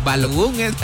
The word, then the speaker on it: Balogun: Unos locos ahí, Bolobán le decían, ¿no? Bologun. Balogun es.